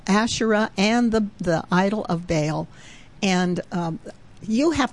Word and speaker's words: Baal: But he took, the Lord said, take your father's bull and a second bull and pull down the altar of Baal, or Baal, if you want to pronounce it that way, which belongs to your father, and cut down the Asherah.